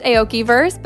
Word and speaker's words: Aokiverse: Steve Aoki has launched his own metaverse and NFT membership platform, I believe to be pronounced Aokiverse, but it's also spelled A0K1verse.